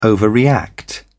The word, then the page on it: overreact: British English